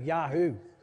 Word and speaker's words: Jehu: So, and then we have guys like Jehu in the Bible. It's spelled J-E-H-U. I love talking about this guy because I can almost get away with calling his name Yahoo.